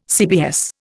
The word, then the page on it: CBS: Indian